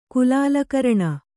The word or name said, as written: ಕುಲಾಲಕರಣ